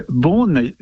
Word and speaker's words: Beaune: Well, Beaune, you said it very well.